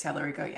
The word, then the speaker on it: Tallarico: And I think, you know, I wanted to see Jasmine Crockett win because I really like her, but I'm not against How do you say his name? Tallarico? – James Tallarico, yeah. – Tallarico.